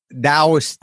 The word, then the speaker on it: Taoist: Yes, I started studying Taoist thought, and it's pronounced with a D, but it's actually spelled with a T. – So it's T-A-O-I-S-T, Taoist? – It is.